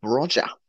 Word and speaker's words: Broja: And names have been flown around, like Southampton's Broja, that's how you say it.